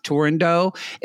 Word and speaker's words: Turandot: If you go into a gay man's house and they have that poster of it's called, you're going to say Turandot, but it's pronounced Turandot.